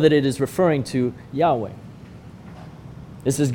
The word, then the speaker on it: Yahweh: But the God of the Old Testament has a name, Yahweh. Yahweh is perhaps the closest guess for how to pronounce something in Hebrew that is a series of consonants.